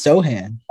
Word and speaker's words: Sochan: So the guy I'm going with, I'm going to butcher his last name, Jeremy Sochan? – I think it's Sohan. Yeah.